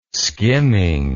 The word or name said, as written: skimming